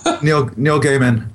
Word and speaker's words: Gaiman: Neil Gaiman.